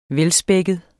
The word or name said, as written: velspækket